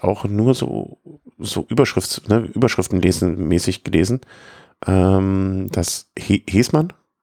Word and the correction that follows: Hessmann: Hessmann spricht man es aus, okay.